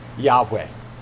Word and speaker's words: Yahweh: And we believe, actually, scholars aren't 100% sure, but we're pretty sure that the way you pronounce that is Yahweh.